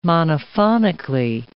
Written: monophonically

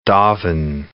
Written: doven

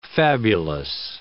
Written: fabulous

